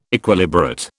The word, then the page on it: Equilibrate: British English